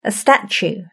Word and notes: a statue: British English

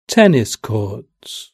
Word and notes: tennis courts: British English

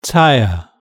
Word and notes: tyre: British English

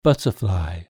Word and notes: butterfly: British English

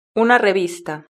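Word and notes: una revista: Spanish (Spain)

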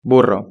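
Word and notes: burro: Spanish (Spain)

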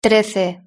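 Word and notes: trece: Spanish (Spain)

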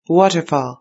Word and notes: waterfall: British English

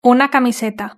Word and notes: una camiseta: European Spanish

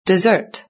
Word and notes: dessert: British English

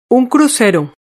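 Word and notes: un crucero: Spanish (Spain)

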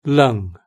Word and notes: lung: British English

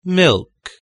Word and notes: milk: British English

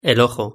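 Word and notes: el ojo: European Spanish

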